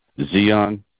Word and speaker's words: Xeon: Okay, the value server is now 52% off 4-core/8-thread Intel Xeon D CPU. How do you pronounce that? – X-E-O. – Xeon? – Yeah, Xeon.